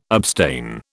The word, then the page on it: abstain: British English